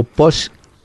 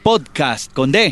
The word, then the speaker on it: podcast: ¿Cómo se pronuncia? Podcast o podcast. – Podcast. Ahí lo está diciendo muy bien usted. Podcast. – Podcast. – Podcast con D. – Ah, sí, sí. Pod, pod con D.